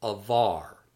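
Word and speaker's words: avar (עבר): Word 40, meaning to transgress or pass through, is pronounced avar, avar, avar.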